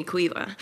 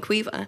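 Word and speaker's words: Caoimhe: So my parents didn't call me Brona, they called me Quiva. And then they had 6 months of calling me Quiva.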